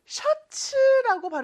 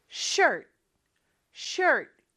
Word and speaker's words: shirt: 아, 그리고 참고로 셔츠라고 발음하면 안 되고 shirt, shirt 이렇게 발음해 주세요.